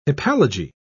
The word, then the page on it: hypallage: British English